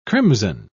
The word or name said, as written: crimson